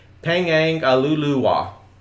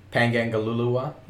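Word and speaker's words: Pangangaluluwa: So, and they have a activity known as, I'm going to say it phonetically, Pangangalulua. – Pangangalulua? – Yeah, that's it.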